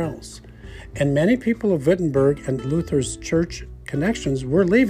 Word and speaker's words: Wittenberg: It looks like Wittenberg, but the Germans pronounce it Wittenberg.